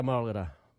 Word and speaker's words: mulgarda: What is a mulgata? Mulgata is a mullet. You can use mulgata in this sentence. There are lots of mulgata in Gatagura, Shark Bay. Can you say mulgata? Mulgata. Mulgata is spelt M-U-L-G-A-R-D-A.